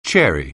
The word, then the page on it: chary: anglais